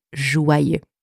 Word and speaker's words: joyeux: For English speakers, seeing the letters J-O-Y together, there is a tendency to want to pronounce this letter combination as joy. Right? Fair enough. But in that word, the letters J-O-Y together are pronounced joie, joie, with wa, wa. like W-A, literally, wa, wa, joie. Then we have the letters Y-E-U.